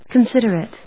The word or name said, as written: considerate